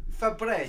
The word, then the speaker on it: Febreze: So, and then another one here, and I know Clayton pointed this one out to me, is Febreze. There's no ease And it's spelled F-E-B-R-E-Z-E. – Febreze.